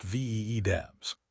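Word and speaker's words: vdev: If you are running ZFS storage, stop building rigid pools and start using modular VDEVs, pronounced VEEDEVs.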